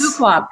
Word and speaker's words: Fuqua: Fuqua, perfect.